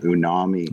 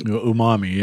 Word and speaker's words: umami: It just richens the almost the how do they pronounce it unami umami.